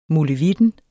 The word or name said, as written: molevitten